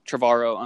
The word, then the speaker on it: Trevorrow: Now, apparently, what's Colin Trevorrow? Is that how you pronounce his name? – Yeah, Trevorrow.